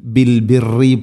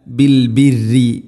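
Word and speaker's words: بالبر: ബിൽ ബിരി, പ്രിയമുള്ള ശ്രോതാക്കളെ പ്രത്യേകം ശ്രദ്ധിക്കുക, ബിൽ ബിരി, ബിൽ ബിറി പറയരുത്, റാന് ശദ്ദ് കസർ, ബിൽ ബിറി അല്ല, ബിൽ ബിരി, ബിൽ ബിരി. തർക്കീഖാണ് ചെയ്യേണ്ടത് ശദ്ദുണ്ടെങ്കിലും. ധാരാളമായിട്ട് ആളുകൾ ബിൽ ബിറി എന്ന് പറയും, മിൻ ഷർറി പറയുന്ന പോലെ. ബിൽ ബിരി.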